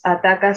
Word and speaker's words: Atakaw: somos Ataca Cine, se escribe A-T-A-K-A-W Cine, que significa qué miedo ataca, significa qué miedo en quechua.